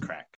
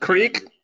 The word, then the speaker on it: craic: Yes, I'm going to let Flex, who has spent time in Ireland, give you some information on Crack, spelled C-R-A-I-C, but pronounced Crack. – Not Creek? – This is a Imperial Irish Stout.